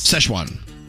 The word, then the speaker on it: Szechuan: Szechuan.